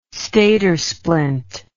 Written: stadersplint

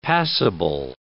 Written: passable

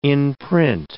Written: in-print

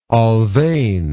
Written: Alfven

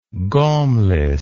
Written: gaumless

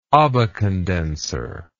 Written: Abbe condenser

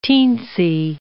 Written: teensy

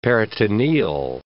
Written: peritoneal

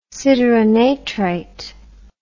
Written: sideronatrite

